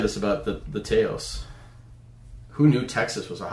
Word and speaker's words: Taos: Taos. Let's see. – Yep. – Taos.